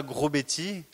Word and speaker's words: Grosbety: Je m'appelle Nicolas Grosbetti. Alors ça s'écrit G-R-O-S-B-E-T-Y. Il y en a qui disent grosse bêtise, gros grosse bêtise, peu importe.